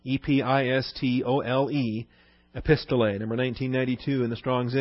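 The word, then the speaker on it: Epistolae (ἐπιστολή): Epistolae, or Epistolae, I guess, accent on the last ada.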